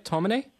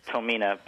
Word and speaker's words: Tomine: Did I pronounce it right? Tomine? – It's close enough. It's Tomina, but you know, I've heard it so many ways that nothing's really right or wrong at this point.